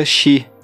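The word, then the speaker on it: Shi (四): Sem falar também que essa soma de 1 e 3, o 4, no Japão, se pronuncia Shi, que é a mesma pronúncia de morte.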